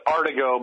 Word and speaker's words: Artigo: Well, it's Artigo, but Artigo. Artigo's fine. Yeah, I'm not picky.